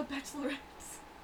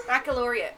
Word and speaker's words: Baccalaureate: Anyways, actually, so IB stands for International Baccalaureate Program. International Bachelorettes. – No, how do you say it? – You're accurate. You could. – Baccalaureate.